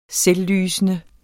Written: selvlysende

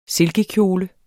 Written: silkekjole

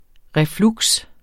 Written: refluks

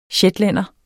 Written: shetlænder

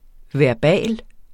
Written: verbal

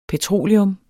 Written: petroleum

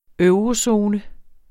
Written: eurozone

